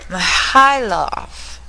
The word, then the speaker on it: Mihailov: Pretty good, Malice. It's Mihailov. – Mihailov. Okay, I was close. – You just put the emphasis on the wrong syllable.